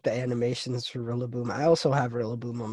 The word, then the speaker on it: Rillaboom: Ooh, Rillaboom. – Rillaboom? Rillaboom? How do you say that? – How do you like Rillaboom?